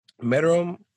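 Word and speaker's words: Meruem: I understand Bisky may be a fun character, but if you're going to put Meru, how do you say his name? – Merum.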